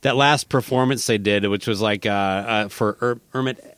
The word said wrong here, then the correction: Ahmet: oh God, I'm going to butcher his name. – Ahmet, yeah, the big time music guy behind them and all that stuff from the label.